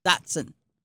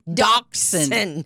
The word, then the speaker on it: Dachshund: Dachshund.